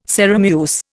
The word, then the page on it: Sarum use: Indian